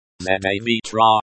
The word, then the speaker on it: Завтра: Завтра. Завтра, the stress falls on the first syllable.